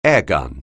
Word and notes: airgun: English

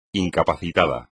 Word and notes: incapacitado: espagnol

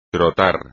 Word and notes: trotar: espagnol

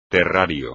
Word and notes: terrario: espagnol

